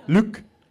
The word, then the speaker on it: Luc: I'm guessing he's French because his name is Luke, but it's spelled Luc. Luke.